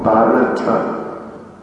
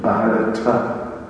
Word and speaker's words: Bharata: Incidentally, this is the Sanskrit pronunciation of the word. We know that the Hindi translation would just be Bharat, but this is Sanskrit language we're using here, and the correct pronunciation is Bharata. If it were just to be Bharat, you would see in the Sanskrit there's a particular mark, which means that the A is not pronounced, if that were to be the case.